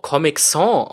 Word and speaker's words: Comic Sans: Also man müsste es, glaube ich, tatsächlich auch Comic Sans aussprechen, weil es eigentlich vom Französischen Sans, also Sans geschrieben, ohne, ja, für ohne kommt.